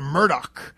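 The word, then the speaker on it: Murdaugh: We talked about the Murdoch, even though it's not spelled Murdoch, but that's how he pronounces his name.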